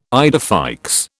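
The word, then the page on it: idée fixe: British English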